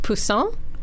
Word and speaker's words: poussin: Okay, I'm going to pronounce this wrong because I keep wanting to say it like the French word for fish, but I think it's pronounced poussin.